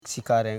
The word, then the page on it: shikare: shi.ka.re